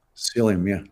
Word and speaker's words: psyllium: Psyllium? – Psyllium, yeah.